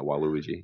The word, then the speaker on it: Waluigi: Yeah, Waluigi.